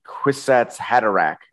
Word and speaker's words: Kwisatz Haderach: The old Reverend Mother mutters that Paul may be the Kwisatz Haderach.